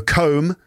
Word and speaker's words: comb: So comb, that's, you know, when you straighten your hair with a comb. The B is silent, so it's comb, not comb, and combed, not combed. Combed and knotted,